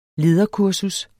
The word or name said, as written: LEDERKURSUS